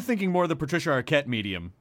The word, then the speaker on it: Arquette: A Patricia Arquette's mediums.